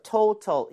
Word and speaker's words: total: So total in the flabless total. 이렇게 Total. – 리을 비슷하게 발음하면 되겠고요.